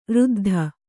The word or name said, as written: ರುದ್ದು